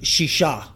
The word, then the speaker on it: shisha: You would say shisha, okay? Shisha is how you say six in Hebrew.